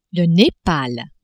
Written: Nepal

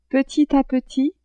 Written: petit a petit

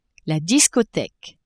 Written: discotheque

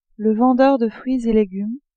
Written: vendeur de fruits et legumes

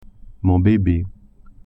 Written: bebe

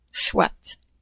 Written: soit

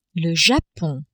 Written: Japon

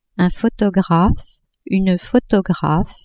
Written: photographe-photographe

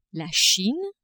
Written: Chine

